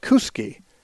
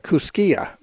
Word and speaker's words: Kuski: And a couple of towns where my friends live, one was called Kuski, which is spelled like Kuskia, but you just say Kuski.